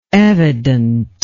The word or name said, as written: evidentness